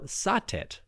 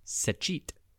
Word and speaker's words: Satet: Now, Satet, or more accurately pronounced Sechit, was a goddess of the south.